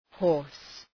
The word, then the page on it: horse: hɔ:rs